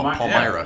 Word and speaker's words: Palmyra: It's an L. Palmyra.